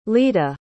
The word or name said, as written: ledah